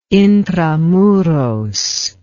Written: intra muros

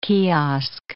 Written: kiosk

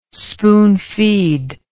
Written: spoonfeed

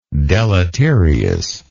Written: deleteriousness

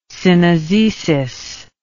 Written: synizesis

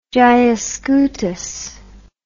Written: gyascutus

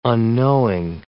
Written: unknowing

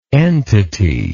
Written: entitatively

